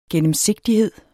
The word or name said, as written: gennemsigtighed